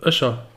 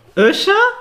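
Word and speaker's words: Öcher: Wie spricht man das richtig aus? – Öscher. – Öscher? Ja.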